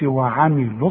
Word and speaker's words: وعملوا: وملاحظ ان انا ما نطقتش الواو عند الوصل، قلت وعملوا الصالحات،